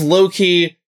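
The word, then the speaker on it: Low Ki: So his name is Loki. It's spelled LOW-KI. – Okay. – So like it's meant to invoke Loki, but it's not.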